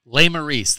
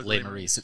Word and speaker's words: Lesmerises: Lesmerises, the last name is pronounced Lesmerises. It's French. It means the wild berries.